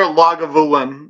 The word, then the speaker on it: Lagavulin: So there's your Lagavulin. You actually pronounce all the letters in that name.